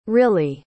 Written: rilley

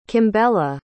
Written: kimbella

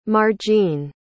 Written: margene

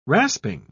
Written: rasping

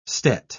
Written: stet

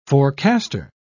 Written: forecaster

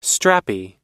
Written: strappy

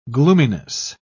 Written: gloominess

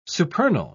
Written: supernal

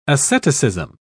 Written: asceticism

